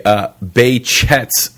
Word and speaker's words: Behçet's: Behçet's disease. – There we go. – And through our extensive research, we've discovered that that is the proper Turkish pronunciation of this disease.